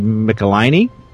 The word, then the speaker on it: Michelinie: And David Michelinie.